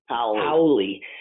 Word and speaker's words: Hawley: Is that how it's pronounced? Hawley or Howley? – Hawley. – Hawley. Hawley. – Hawley, actually.